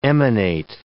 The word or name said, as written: emanate